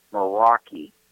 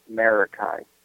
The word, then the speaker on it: Meraki: We're purchasing their wireless, and once again, their actual name is Meraki, not Marakai.